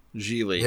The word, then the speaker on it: Gigli: Geely's, I always think of Geely's like a Chinese car company because it's a Chinese car company, but it's spelled like Jiggly, but it's not supposed to.